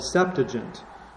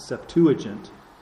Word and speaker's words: Septuagint: which is called the Septuagint, or some people pronounce it Septuagint.